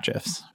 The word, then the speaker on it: GIF: Ultimately, the applications could be sending HTML cat GIFs. All Istio is really for. – I should point out that the official pronunciation on this podcast is GIF.